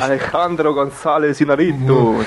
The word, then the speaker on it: Alejandro González Iñárritu: Das musst du nochmal imbrünstiger aussprechen. – Ich weiß gar nicht, wie man es richtig ausspricht. – Alejandro González Iñárritu. Das klingt doch viel besser.